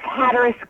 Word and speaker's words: Hatteras: Is that how you say it? Hatteras? It's Hatteras Girl. Hatteras Girl.